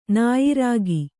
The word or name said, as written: ನಾಯಿರಾಗಿ